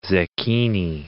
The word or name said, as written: zecchini